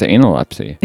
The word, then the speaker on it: Analepsy: And then after that, I don't know if I'm saying this right, Analepsy? Analepsy? – Yeah. – I feel like it's Analepsy. – Yeah, I think so too.